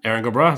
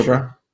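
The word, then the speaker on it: Erin go bragh: Aaron Gobra, is that what I'm supposed to say? – Aaron Gobra. It's one of the things you can Aaron say. – Aaron Gobra.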